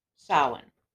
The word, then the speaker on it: Samhain: The tradition originated with the ancient Celtic festival of Samhain, spelled totally different than how you pronounce it. S-A-M-H-A-I-N.